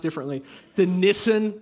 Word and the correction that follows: Nissan: factory. Nissan. Yeah, thanks. I was saying it wrong.